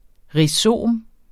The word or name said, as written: rhizom